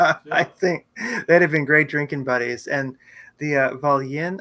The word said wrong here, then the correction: Volyn: I think it was. I forget the exact pronunciation, but that was like 100K. – The Volyn, W-O-L-Y-N.